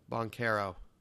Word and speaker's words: Banchero: And we know we've been a little slumped recently, but I hope I say this right, Banchero and Wagner. – Banchero. – Is that, no, it's not. You're lying to me. Okay. It's Banchero? – Banchero.